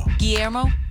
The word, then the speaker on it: Guillermo: Jimmy Kimmel, his sidekick, Guillermo. How do you say his name? – Guillermo.